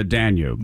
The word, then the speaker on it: Danube: In Hungary, water levels on the, and I may be saying this wrong, is it the Danube? The river? – Yeah, the Danube.